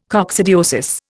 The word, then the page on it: coccidiosis: Indian